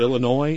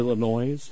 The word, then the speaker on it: Illinois: Is it Illinois? Is it Illinois? – Oh, please. It's a silent S.